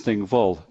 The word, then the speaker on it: Thingwall: So Thingwall is Thingvol, which means the assembly field, the parliament field.